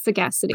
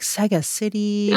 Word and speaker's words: Sagacity: It's called Sagacity, correct? – That is the right pronunciation. Yes, Sagacity. – Perfect. Because I was like Sega City.